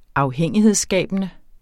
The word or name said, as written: afhængighedsskabende